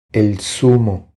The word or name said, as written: El zumo